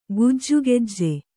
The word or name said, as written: ಗೊಜಗೊಜ